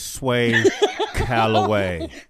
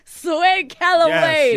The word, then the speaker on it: Sway Calloway: How do you pronounce your name? – It's Sway Calloway. – Sway Calloway.